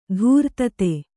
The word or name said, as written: ಧೀರೋದಾತ್ತ